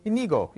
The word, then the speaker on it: Inigo: How do you say his name? Inigo. Yeah, that's right.